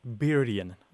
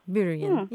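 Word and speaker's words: byrgan: It's B-Y-R-G-A-N, and it's pronounced something like Byrian. – Byrian, yeah.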